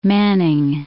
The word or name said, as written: Manning